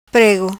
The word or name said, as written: Prêgo